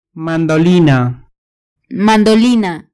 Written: mandolina

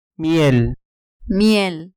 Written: miel

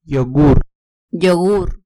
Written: yogur

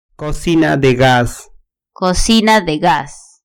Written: cocina de gas

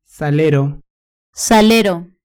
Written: salero

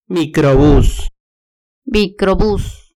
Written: microbus

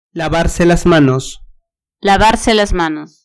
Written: lavarse las manos

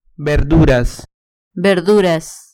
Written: verduras

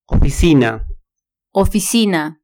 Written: oficina

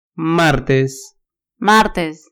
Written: martes

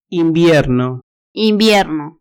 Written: invierno

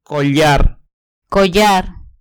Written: collar

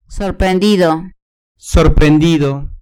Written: sorprendido

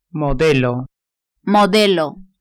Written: modelo

